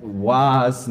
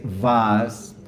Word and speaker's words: was: Vemos cómo la W se pronuncia como una V. Allí no tenemos que decir was, no, vamos a decir vas con V.